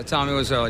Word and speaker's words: Tommy Wiseau: This is one of my heroes, Tommy Wiseau. I hope I'm pronouncing that right. – Yeah, Tommy Wiseau.